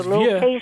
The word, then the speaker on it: vieux: I believe you were trying to pronounce V-I-E-U-X. – And that's correct. – And that is vieux.